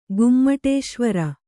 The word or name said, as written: ಗೊಮ್ಮಟೇಶ್ವರ